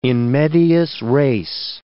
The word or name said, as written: in medias res